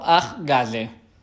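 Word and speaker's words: Ahgase: Que es pronuncia um, I Got Seven o Ah Gaze.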